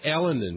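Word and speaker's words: Elenin: Comet Elanen, you were talking about that before. Is that how you pronounce it, Elanen? – Comet Elanen, yes.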